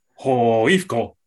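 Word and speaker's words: HIFK: I'm pretty sure on the stands the chant goes like Hoifko. Hoifko. – Okay. There we go. So there's three pronunciations and none of which are Hifki.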